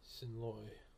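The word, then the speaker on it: xin lỗi: X-I-N. – X-I-N. – L-O-I. – Hasson Loy. – Yeah, it is it's pronounced Hasson, I-N, but it's spelled X-I-N.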